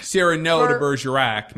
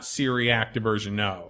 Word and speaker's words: Cyrano de Bergerac: Cyrano de Bergerac, not Cyriac de Bergerac.